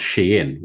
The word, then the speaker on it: Shein: Okay, now a lower way down doing this is, I actually watched a program the other day on the BBC, which was about Shein, actually pronounced Shein, which is a worldwide retail company selling stuff for dirt cheap.